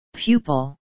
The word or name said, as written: pupil